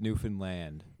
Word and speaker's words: Newfoundland: Say it right by. – Newfoundland. – So but for those of you listening, it's Newfoundland.